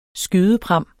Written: skydepram

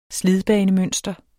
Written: slidbanemønster

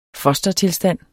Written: fostertilstand

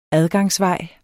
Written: adgangsvej

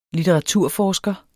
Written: litteraturforsker

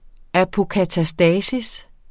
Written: apokatastasis

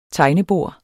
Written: tegnebord

